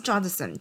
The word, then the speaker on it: Judson: 是 Judson 吗？还是 Judson？哦，应该是 Judson，贾德森。